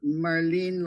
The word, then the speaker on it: Marlene: I mean, her name was Marlene, and she used to pronounce it, Marlene like Lenin, So I think there is, you know, there can be a bit of that sort of psychosis that sets in later.